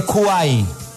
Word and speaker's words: Kwai: Les recomiendo la aplicación de Kwai. – Ok. – Se escribe K-W-A-I latina. K-W-A-I latina, pero se pronuncia Kwai.